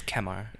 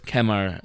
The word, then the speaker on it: Kemar: You know, I was reminded that his name, his pronunciation in the pronunciation guide in the media kit. – It's Kemar. – It very specifically says Kemar Lawrence.